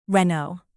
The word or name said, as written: renault